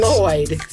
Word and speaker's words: Lloyd: Put your tongue where you're going to about to say sugar and then instead blow out on the sides. – Say Lloyd.